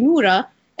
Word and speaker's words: Nora: So it's not a white people thing, but it's spelled N-O-R-A, so people pronounce it Nora, but it's actually Nura.